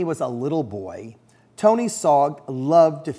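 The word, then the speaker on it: Sarg: Sog rhymes with Og.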